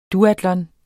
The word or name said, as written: duatlon